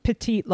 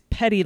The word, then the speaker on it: petty: It was petite larceny. – Yeah. – It's petty larceny. That's how that's pronounced.